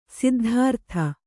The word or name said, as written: ಸಿದ್ಧಾರ್ಥಿ